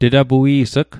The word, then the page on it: tetapui's'g: Mi'gmaq